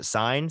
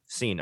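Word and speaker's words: Cine: I guess I'll go safety here, and I'll take Lewis Seen, or is it Sign? Seen? Do you know how to say his name? Seen. Okay, that's what I thought.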